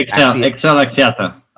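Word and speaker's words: XL Axiata: Excel Axiata.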